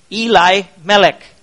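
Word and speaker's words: Elimelech: The name of the man was Eli-Melech. That's a better way of pronouncing it.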